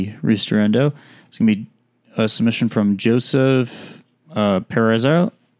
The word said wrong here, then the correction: Perazzo: Wow. Really? Try again. Come on. Keep going. – Perezzo. – How do you spell it? – Perazzo. – Perazzo. Yeah, Perazzo.